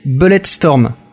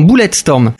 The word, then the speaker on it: Bulletstorm: Tu vas me dire si je le prononce bien cette fois, j'ai répété toute la nuit, c'est Bulletstorm. Non, Bulletstorm. – Bulletstorm.